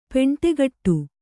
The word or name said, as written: ಪೆಂಟೆಗಟ್ಟು